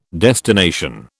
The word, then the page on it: destination: British English